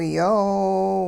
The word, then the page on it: yoow: hupa